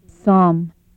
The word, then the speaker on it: sam: Sam is pronounced as sum.